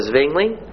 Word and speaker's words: Zwingli: Now we move to the Swiss Reformation, and this will associate Ulrich Zwingli, U-L-R-I-C-H, with Zurich, a city in Switzerland. Z-W-I-N-G-L-I. It's pronounced in English like it is spelled with a T-S-V. Zwingli. That's the correct English pronunciation.